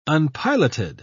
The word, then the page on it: unpiloted: British English